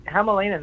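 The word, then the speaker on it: Hämäläinen: Hamalainen? Hamalainen? – Yeah, that's Hamalainen. That's pretty good.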